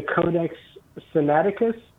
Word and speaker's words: Codex Sinaiticus: is the Codex Sinaiticus. I hope I'm pronouncing mm-hmm. that right. – Close enough.